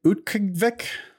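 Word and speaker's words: Utqiagvik: Utqavik.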